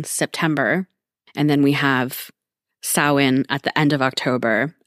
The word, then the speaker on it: Samhain: That's how the word is spelled, but it's actually a Gaelic word, I think, or Celtic in origin. And so it's pronounced Samhain.